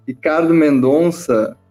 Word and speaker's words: Ricardo Mendonça: Nós estamos aqui com o irmão Ricardo Mendonça, que é, dire...